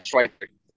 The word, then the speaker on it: Schweitzer: Yeah, Schweitzer.